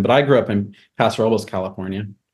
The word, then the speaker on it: Paso Robles: It's also, I grew up in a Latino community, and we'd be talking Spanglish, and but even they would say Paso Robles. You know, it's very precise pronunciation.